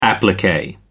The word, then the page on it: applique: American English